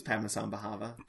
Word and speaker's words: Padmasambhava: I think it's Padmasambhava. – I think you're saying it right. – The abbot and Tomney and this mysterious Padmasambhava.